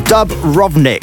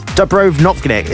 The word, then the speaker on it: Dubrovnik: Said the best place I ever been to was, if I could pronounce this right, Dubrovnik. Dubrovnik. It's in Croatia. Dubrovnik.